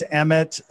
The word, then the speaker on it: Emmet: Yeah, so it's Emmet E-M-M-E-T. I always say my parents spelt it wrong, so it's E-M-M-E-T dot Scott on Instagram.